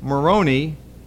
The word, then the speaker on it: Moroni: In 1823, an angel named Moroni, I love that name. You have to make sure you pronounce the silent, the last I. Don't make it silent, okay? The angel Moroni allegedly appeared to Smith, and he told him about these golden tablets that were buried.